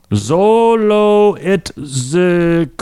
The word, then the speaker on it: Xoloitzcuintli: This is spelled, ready? Okay, I'll give you guys each a chance to pronounce this. X-O-L-O-I-T-Z-C-U-I-N-T-L-I. – It's like Mexican or something, right? – It is. They abbreviate it typically Zolo.